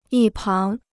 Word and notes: 一旁: yī páng

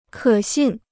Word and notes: 可信: kě xìn

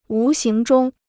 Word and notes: 无形中: wú xíng zhōng

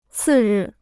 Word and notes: 次日: cì rì

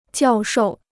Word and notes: 教授: jiào shòu

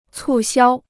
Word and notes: 促销: cù xiāo

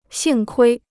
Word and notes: 幸亏: xìng kuī

